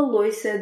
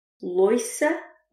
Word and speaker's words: louça: Uma dica interessante quanto à palavra louça. Esta palavra tem 2 pronúncias possíveis. Louça ou louça. Eu digo sempre louça, mas a outra opção, louça, também está correta.